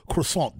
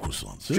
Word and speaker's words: croissant: Today is also National Croissant Day. You like croissants? Oh, no. Well, you got to say it right, though. – I love croissants. – Tell them how to say it. It's croissant.